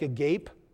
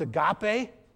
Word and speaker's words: agape: It's kind of confusing because if you see it in the English letters, it looks like agape, but it's pronounced agape.